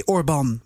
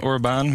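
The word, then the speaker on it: Orban: Ik geloof dat de klemtoon dan anders ligt. Weet jij dat? – Ja, zat het ook Orbán, weer? Inderdaad. Voor Hongaars is dan Orbán met een... met een accent op de... maar als het goed is, is die naam ook oorspronkelijk van het Hongaars. Dan zou je hem ook kunnen uitspreken als Orbán.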